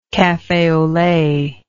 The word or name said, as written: cafe au lait